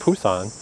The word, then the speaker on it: Busan: But the thing that did surprise me is he was saying how the second biggest city, Busan, which is where I learned that he pronounces it Pusan, I mean, it's still pretty big.